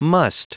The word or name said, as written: must